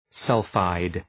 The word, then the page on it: sulphide: ‘sʌlfaıd